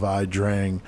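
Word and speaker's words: Ia Drang: Now, river in Vietnamese is I-A, but pronounced just I. So the battle was known as the Battle of I Drang.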